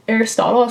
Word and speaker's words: Aristotle: Aristotle, that's how you say it? – Yeah.